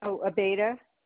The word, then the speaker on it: Abeyta: Abeta. – Spell it for me. – A-B-E-Y-T-A.